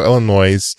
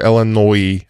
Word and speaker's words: Illinois: cellular network in Chicago, Illinois, or Illinois. I get, I think the S is silent if I remember high school, October 13th, 1983.